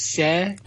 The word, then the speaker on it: Cher: So, you know, and of course the other big one was, of course, the fabulous Cher, although I'm told by Americans it's pronounced Cher.